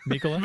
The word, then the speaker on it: Mikkola: It caused a three on one on uh, Niko Mik- Mikkola. Mikkola? – Making sure I'm saying it right now. Mikkola. Uh, Mikkola, reco- uh, he covered the pass well, I thought, but Husso just got beat uh, off a nice, nice shot from Hunt.